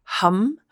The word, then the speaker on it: ham: This week's mantra is the Sanskrit word hum, and it's spelled H-A-M, but pronounced hum.